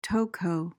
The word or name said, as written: toco